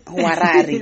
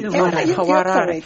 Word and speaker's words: Hwarari: And I met an artist named Carmen, forgive me if I don't pronounce this right, Huarari. – Okay. – H-W-A-R-A-R-I. – Let's say this. – Huarari. – Huarari.